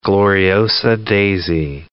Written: gloriosa daisy

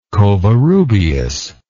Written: covarrubias

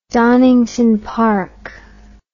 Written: donington park

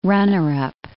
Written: runner-up

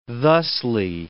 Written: thusly

